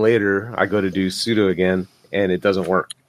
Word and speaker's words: sudo: And sometime later, I go to do sudo. And I'm like, crap, I don't know. My password's not working. What the hell's going on? – It's pronounced sudo. – Whatever. – Sudo's right. You're right.